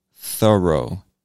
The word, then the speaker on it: thorough: One tip that I can give you to help you pronounce this word more easily is to think of the word thorough as if it were two separate words. The first one, thur, like Thursday, thur.